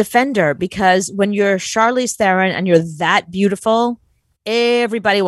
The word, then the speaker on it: Charlize Theron: Charlize Theron. Is that how you say your name? – Yeah. Charlize Theron.